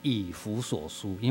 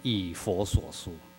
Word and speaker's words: 以弗所书: 谢谢德明兄刚刚再一次的提醒我，这卷书是念以弗所书，因为我常念成以佛所书啊，再一次提醒我不要念错了。